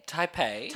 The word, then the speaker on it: Taipei: On July the 28th, 2013, in Tapai, Taipei? – I'm having an absolute, how do you say it? – Taipei. – Taipei.